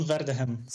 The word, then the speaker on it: Verdegem: So, and say your last name just to make certain that I came close. – Well, it's close. It's Simon Verdeken.